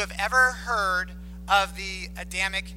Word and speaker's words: Adamic: No, okay, Adamic is spelled A-D-A-M-I-C. Okay, Adam, you know, Adam and Eve.